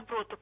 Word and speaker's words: доброту: Доброту, правильно. Тут и ударение, простите меня. – Прощаю. – И ударение – доброту, да, простите.